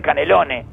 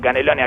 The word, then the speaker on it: Canelones: Que es lo que, como le dice la gente de Canelones a Canelones. La gente que es verdaderamente de Canelones, ¿verdad? Yo no conozco ningún ciudadano de Canelones que pronuncie la S final de Canelones.